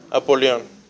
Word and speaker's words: Apolión: Y en griego se dice Apolión. No Napoleón, ¿eh? No es Napoleón, es sí, sí, no es francés, es griego.